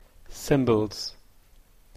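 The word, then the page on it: cymbals: Canadian English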